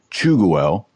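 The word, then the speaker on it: Tschugguel: I'm here with Alexander Chuguel.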